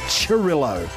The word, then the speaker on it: Cirillo: It's pronounced Cirillo. C-I-R-I-L-L-O.